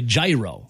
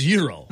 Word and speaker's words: gyro: I still hear people say gyro. – Same here. – That are old, like much older than me. Yeah. And I know they've heard it correctly pronounced a lot longer than I have. – I know you know this. – I know you know it's Euro. Why do you keep saying gyro? – Stop saying that.